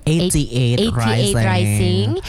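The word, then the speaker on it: 88rising: 88 Rising.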